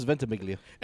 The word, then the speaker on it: Ventimiglia: I thought it was Ventimiglia. – I'm pretty sure you're right.